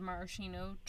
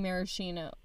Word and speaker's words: maraschino: It took this episode for me to learn that I was pronouncing maraschino. What was I saying before? – Maraschino. – Maraschino. – Maraschino. – Maraschino. I wouldn't even... There wasn't even an R in it.